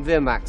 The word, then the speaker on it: Vermax: He says Vermax, like V-E-E-R. – It's good you're here, young prince. You're needed to patrol the skies on Vermax. – Did you hear what I said? – Vermax. It's V-E-R-M-A-X, so I would say Vermax.